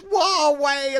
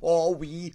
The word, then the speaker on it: Huawei: And they went, it's Huawei, it's not Huawei, or whatever I said.